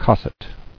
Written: Cosset

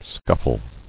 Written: Scuffling

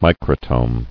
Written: Microtome

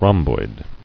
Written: Rhomboidal

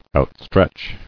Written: Outstretch

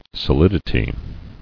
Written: Solidity

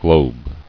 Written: Globing

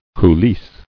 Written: Coulisse

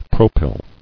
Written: Propylic

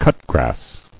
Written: Cutgrass

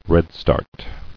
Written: Redstart

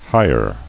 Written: Hirer

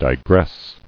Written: Digress

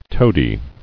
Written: Toadied

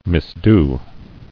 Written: Misdone

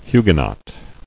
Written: Huguenot

